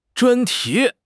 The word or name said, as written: 专题